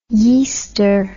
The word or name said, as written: yeaster